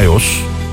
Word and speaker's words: iOS: İster Android tabanlı olsun telefonunuz, ister iOS ya da iOS diye okunuyor, isterse Huawei, hiç fark etmez, hepsinde varız.